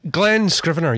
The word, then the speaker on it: Glenn Scrivener: And I tell you, something I've really appreciated, and I noticed that you'd put it on the Solas Facebook page, was Glenn Scrivener. Is that how you pronounce his name? – Glenn Scrivener, yeah.